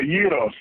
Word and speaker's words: gyros: It's called Gyros. The G is silent.